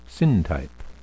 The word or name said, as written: syntype